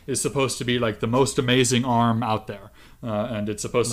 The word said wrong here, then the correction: Arum: Now, it's not pronounced arm. Wikipedia says it's specifically pronounced arum.